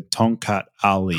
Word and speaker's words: Tongkat Ali: So it's T-O-N-G, Tong, and then Kat, K-A-T. And then it's space, and then it's A-L-I. So Tongkat Ali.